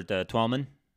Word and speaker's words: Twellman: Our boy Taylor Twellman. Is that how you say his name? – Yeah. – Twellman.